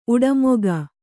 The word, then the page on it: ಉಡುಮೊಗ: uḍumoga